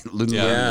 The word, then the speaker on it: Lululemon: Lululemon is how you pronounce it.